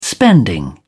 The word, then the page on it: spending: British English